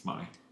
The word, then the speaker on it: Monaghan: The G is silent. Okay. – It's Monaghan.